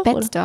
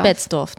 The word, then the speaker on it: Betzdorf: Betzdorf. Betzdorf, doch.